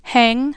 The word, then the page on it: hang: American English